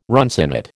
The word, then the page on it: runcinate: American English